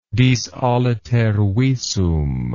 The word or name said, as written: dis aliter visum